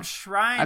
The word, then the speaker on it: Schreiner: It's Tom Schreiner. – I've said his name wrong Rainer. – The whole time.